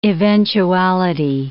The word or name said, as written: eventuality